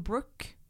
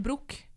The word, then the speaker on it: Brooke: Men det samme som Brooke. Navnet Brooke. det på en måte en engelsk uttale, og på norsk så blir det brok.